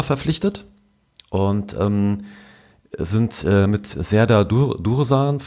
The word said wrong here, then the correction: Dursun: von ähm, muss mal aufpassen, dass ich es richtig ausspreche. – Ja, Dursun. – Dursun, genau, von, ähm, vom Kräuter Fürth zu uns gekommen.